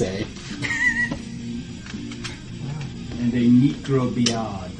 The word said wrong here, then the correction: microbead: A microbead.